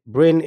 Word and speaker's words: Brin: Download Brin. It's spelled B-R-I-N.